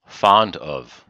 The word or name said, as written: fond of